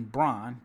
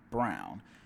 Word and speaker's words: Braun: I keep wanting to say Christian Braun, but it is Christian Brown. But it is spelled like Braun, which is confusing.